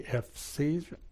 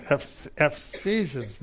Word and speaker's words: Ephesians: FCs. Come on, tell us. Hey, he said it right. That's it. Ephesians.